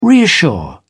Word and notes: reassure: British English

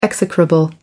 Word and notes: execrable: American English

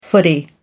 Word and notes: footy: American English

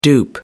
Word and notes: dupe: American English

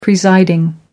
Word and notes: presiding: American English

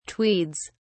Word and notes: tweeds: British English

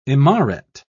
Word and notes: imaret: British English

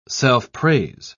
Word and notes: self-praise: British English

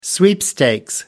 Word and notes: sweepstakes: British English

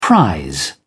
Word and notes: prise: British English

